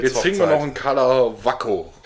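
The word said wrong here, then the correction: Karlovačko: Kalavacko. Ich war mal in Kroatien, habe mich gefragt, wie spricht man das aus und dann habe ich es mir gemerkt. – Kalavacko.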